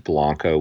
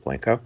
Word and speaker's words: Blanco: It should be pronounced Blanco. We pronounce it Blanco.